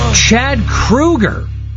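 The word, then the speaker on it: Chad Kroeger: Chad Krueger. Like Freddy Krueger. That's how you say it. I've been saying Chad Kroger all these years.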